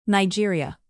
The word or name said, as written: nigeria